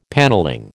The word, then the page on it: panelling: American English